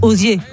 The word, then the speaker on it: Ozier: Évidemment, j'ai très très mal prononcé l'identité de ce chanteur, comme on dit. – Ozière. Mais à la fois, dites Ozier.